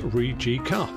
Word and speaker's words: Rijeka: Well, they are Croatian and they are pronounced Rijeka.